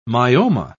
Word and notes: myoma: British English

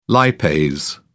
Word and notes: lipase: British English

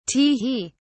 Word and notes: tee hee: British English

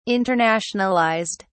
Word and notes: internationalized: British English

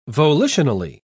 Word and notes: volitionally: British English

